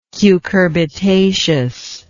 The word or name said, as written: cucurbitaceous